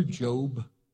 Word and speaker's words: Job: But it's spelled like job, J-O-B, but his name is Job.